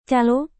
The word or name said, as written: kalo